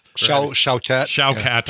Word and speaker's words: Shawkat: And I don't want to leave out Aaliyah Shawkat or Shawkat. – Shawkat. – Yeah. – I really don't know how it's pronounced, but from Arrested Development, she played Maybe.